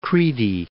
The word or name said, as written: Kriti